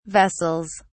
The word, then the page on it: vessels: British English